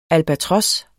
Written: albatros

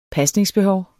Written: pasningsbehov

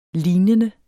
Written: lignende

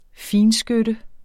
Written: finskytte

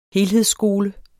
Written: helhedsskole

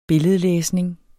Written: billedlæsning

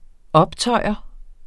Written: optøjer

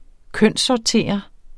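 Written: kønssortere

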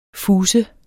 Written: fuser

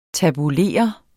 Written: tabulere

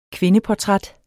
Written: kvindeportræt

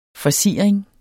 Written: FORSIRINGERNES

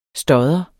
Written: stodder

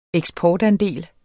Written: eksportandel

